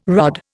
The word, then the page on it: rod: Indian